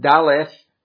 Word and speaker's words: Daleth: This opens the fourth Hebrew alphabet, which, as I would suppose, is pronounced Daleth.